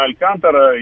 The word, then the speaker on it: Алькантара: Я помню, Сергей просто, Сергей, он был любителем всего испанского, и он произносил на испанский манер Алькантара. Но вообще Алькантара, конечно, да.